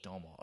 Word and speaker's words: Domhnall: It's Domhnall Gleeson. It's like tonal with a D. – Domhnall.